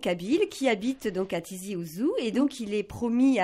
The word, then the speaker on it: Tizi Ouzou: Alors, je ne sais pas si je prononce bien. – Non, très bien, c'est Tizi Ouzou. – Voilà, Tizi Ouzou, c'est joli, c'est chantant comme nom aussi.